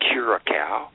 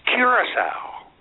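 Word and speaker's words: Curaçao: And I turn around, and she says, "It's not Curacao. It's Curacao."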